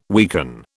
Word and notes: weaken: British English

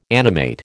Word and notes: animate: American English

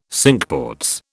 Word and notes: Cinque Ports: British English

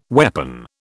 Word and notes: weapon: British English

